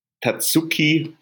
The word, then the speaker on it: Tatsuki: Tetsuki, äh, wie spricht man es aus? Äh, da kann ich dir nicht helfen. Ich weiß auch gar nicht, wen du meinst. – Tatsuki Fuyumoto, die Chainsaw Man macht, also das musst ihr ja irgendwann mal.